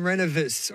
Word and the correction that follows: Rendezvous: or Renovace or whatever how you pronounce it. – Divine Rendezvous. – Oh, Rendezvous.